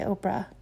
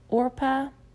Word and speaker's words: Orpah: And their boys ended up marrying two women named Orpah, which I always want to say Oprah because it's spelled almost the same, Orpah and Ruth.